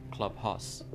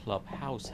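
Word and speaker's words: Clubhouse: کلاب هاست یا کلاب هاس خب ما این می این پلتفرم رو درستش رو اگر بخوایم تلفظ بکنیم کلاب هاوس هست هاوس یعنی همون به مفهوم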